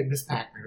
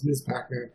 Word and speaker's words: Ms. Pac-Man: But I'm sorry, and I'm even saying it wrong. People will say Ms. Pac-Man. It's Ms. Pac-Man.